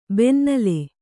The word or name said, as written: ಬೆನ್ನಲೆ